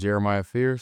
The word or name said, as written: Jeremiah Fears